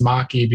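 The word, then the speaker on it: Makie: I always call it Maki, but I talked to the creator and he was like, it's Maki because it has Japanese origins.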